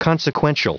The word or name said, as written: consequential